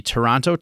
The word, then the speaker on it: Toronto: The names that they trademarked are the Toronto Torch. Um, I think I'm going to get heat from Toronto people there because I, I you're not supposed to pronounce that second T, and I did.